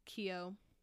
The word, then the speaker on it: Keough: Also starring so many people: Suki Waterhouse, Sam Claflin, and I can never pronounce her name right. Riley Keough. – There you go.